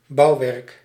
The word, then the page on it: bâtiment: [bɑ.ti.mɑ̃]; France